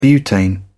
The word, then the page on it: butane: American English